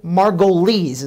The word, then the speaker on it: Margolies: I'd like to introduce you to a personal friend of mine and a business partner of mine, Mr. Michael Margolies. Is that how you say it? – That is.